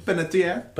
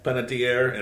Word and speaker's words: Panettiere: Donald Faison, Hayden How do you say her last name? – Penetier. – Penetier and Ryan Gosling.